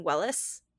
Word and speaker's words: Welles: So if I'm – maybe it's Orson Welles. I don't know. I apologize if I'm Welles. – Pronouncing it. – It's Welles. – It is Welles.